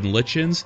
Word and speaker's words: Lichens: That's letter M, Jordan Litchens. Last name is spelled L-I-C-H-E-N-S, just like lichens the fungus, but unlike the fungus, I will actually talk to you.